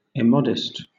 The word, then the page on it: immodest: British English